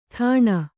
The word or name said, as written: ಟನ್